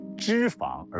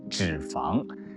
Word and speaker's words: 脂: 先纠正一下咬文嚼字小编刚才读错的一个字啊，是脂肪而不是脂肪。现代汉语词典第 7版当中查一下，这个字只有一个读音脂，而且小编啊真的没必要把这个脂肪当成敌人。